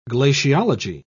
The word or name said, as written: glaciology